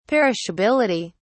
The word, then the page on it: perishability: British English